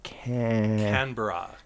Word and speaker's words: Canberra: A new crop of aerials in Dhaka and Canberra. I'm saying that wrong. – Canberra. – Canberra.